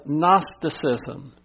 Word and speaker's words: Gnosticism: And then we have Gnosticism. The G is silent.